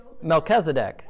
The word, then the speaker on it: Melchizedek: When it says, I can't pronounce his name, Melchizedek.